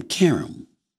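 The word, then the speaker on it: Qaqish: Let me pronounce her name. Karam. Because she always gets on me on first take when I don't pronounce her name right.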